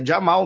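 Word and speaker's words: Jamahal: Falando uma coisa aqui que eu aprendi esses dias é Jamal mesmo, tá? Né? Eu também falava de Amarral, é Jamal. Esse H aí é. É mudo. Esquece, é mudo.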